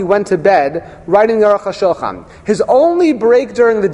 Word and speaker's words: Aruch HaShulchan: The Pasuk there says, Aruch HaShulchan, not Aruch HaShulchan, Aruch HaShulchan.